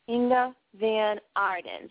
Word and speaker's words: Inga Van Arden: And I want to make sure that I have a funky Boston accent, so I don't mean to butcher people's names, but what is the correct pronunciation of your whole name? – Inga Van Arden. – Inga Van Arden. So I said it right. Is that correct?